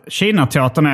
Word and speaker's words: China-teatern: Men säger du Kina? Jag tror att China-teatern är det officiella uttalet.